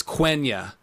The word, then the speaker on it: Quenya: I said Quenya, it's Quenya, not like the country Quenya, the elf language that Tolkien made up. It's Quenya.